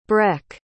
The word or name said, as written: breck